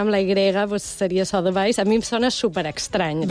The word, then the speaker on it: Sotheby's: Com se pronuncia? Perquè no ho han sabut mai. – Nosaltres, a París, sempre, sí, sempre ho hem pronunciat Sotheby's. – Sotheby's.